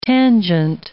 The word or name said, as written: tangent